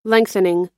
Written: lengthening

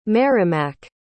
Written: merrimack

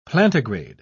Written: plantigrade

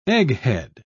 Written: egghead

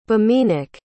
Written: biminak

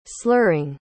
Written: slurring

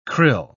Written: krill

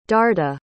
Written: darda

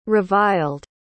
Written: reviled

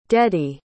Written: deady

